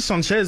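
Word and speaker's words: Sánchez: C'est pas un ami de hispanophone, mais Alexis Sanchez, comme sans la prononciation du S, c'est généralement ce que j'ai entendu.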